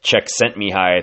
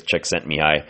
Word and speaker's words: Csikszentmihalyi: And if you've ever heard of the book called Flow, they really cite this one person's body of research, and this Mikhail Csikszentmihalyi, I think is how you say his name. I've seen it written a lot, never heard it said, but I think it's Csikszentmihalyi.